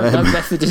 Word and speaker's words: Beathard: I have always referred to him as CJ Beathard. I didn't know that it was pronounced any other way. – No, Beathard is the correct pronunciation.